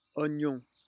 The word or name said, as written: oignon